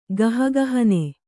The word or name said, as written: ಗಹಗಹನೆ